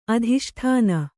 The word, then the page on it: ಅಧಿಷ್ಠಾನ: adhiṣṭāna